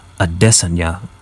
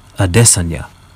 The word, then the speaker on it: Adesanya: And it is, yes, Adesanya. Everybody else is totally saying it wrong, apparently. I was listening to, he came on the Ariel Helwani show, and yeah, they both made a point of, yep, saying it is in fact Adesanya, emphasis on the dis, as in I dis on ya, which he does.